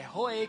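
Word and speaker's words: Rohi: é Jeová Roê, né? É Roê que se fala, apesar de ter um I ali, é Roê. Se escreve Roí e se lê Roê, né?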